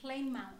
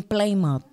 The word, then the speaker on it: Plymouth: En noviembre de 1620, colonizadores colonizadores, perdón si lo dije bien, ingleses, desembarcan en Massachusetts y establecieron en Colonia, ¿cómo dijimos que se, se pronuncia esto? – En Plymouth. – En Plymouth. Es que me gusta preguntar porque dije, ¿lo pronuncio mal?